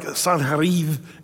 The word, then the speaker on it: Sennacherib: One of the things that's largely lacking, by the way, in history is the message of Sennacherib in the Bible. It's really more like Sanherib is the way you say his name.